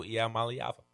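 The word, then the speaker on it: Iamaleava: Nico Iamaliava. – There you go. You're rolling with that last name now.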